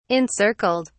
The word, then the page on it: encircled: British English